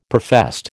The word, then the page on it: professed: American English